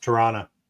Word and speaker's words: Toronto: So pronounced Toronto. – Toronto? – Toronto. You don't pronounce the T. You don't pronounce the second T.